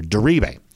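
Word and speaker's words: Diribe: So I think, you know, yesterday Connor Riley and I both were taking a shot at this, and I think we both had the enunciation on the wrong syllable there on the last name, coming in the middle syllable here. Deribe, I believe, is the way we're going to go with that.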